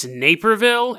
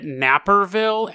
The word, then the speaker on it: Naperville: They mispronounced a city. It's Naperville, and the host called it Napperville, and then made a joke about people taking naps.